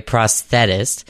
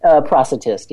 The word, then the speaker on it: prosthetist: Now, by day, you are a prosthetist. I guess, did I say that correctly? – Prosthetist, yeah. – Prosthetist. Okay, you learn something new every day, I guess.